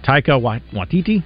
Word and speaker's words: Taika Waititi: So apparently, a Thor five is happening with uh, is it Taika Waititi? Is that you say his name? Mm-hmm. Yeah.